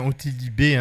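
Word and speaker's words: Ottilie B: Et donc, c'est bien Otili B, hein, d'ailleurs, si c'est... Elle le reprécise dans l'interview que c'est bien... C'est pour qu'on ne prononce pas à l'anglaise qu'elle l'a mis entre crochets.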